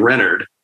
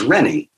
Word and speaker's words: Reynard: but he pronounced his name Reynard. And so because my friend pronounced his name that way, I always thought of my character as Rennie, even though it rather looks like Rainey.